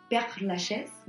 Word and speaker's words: Père Lachaise: Al cementerio de Père Lachaise, ¿lo pronuncié bien, Ali? – Très bien, mon amour. – Très bien, très bien.